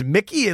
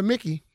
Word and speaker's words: Mikey: It's Mickey. It looks like it's spelled Mikey. – But they call it Mickey.